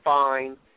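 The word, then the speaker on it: Phine: but it's P-H-I-N-E, but it's pronounced fine. As if, you know, like a lot of the females would describe a very attractive gentleman. You would say he's fine.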